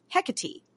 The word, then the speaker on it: Hecate: Now, you can say Hecate or Hecate. In, you know, Greek, in, like, the Greco-Roman language, you would usually pronounce the E as E. Okay. But A also works. So Hecate, Hecate, both work.